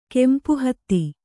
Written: ಕೆಂಪುಹತ್ತಿ

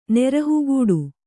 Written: ನೆರಹುಗೂಡು

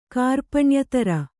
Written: ಕಾರ್ಪಣ್ಯತರ

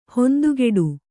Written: ಹಿಂದುಗಡೆ

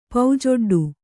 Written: ಪೌಜೊಡ್ಡು

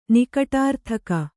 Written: ನಿಕಟಾರ್ಥಕ

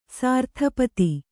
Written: ಸರಿತ್ಪತಿ

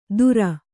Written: ಧುರ

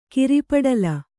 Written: ಕಿರಿಪಡಲ

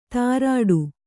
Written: ತಾರಾಡು

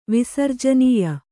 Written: ವಿಸರ್ಜನೀಯ

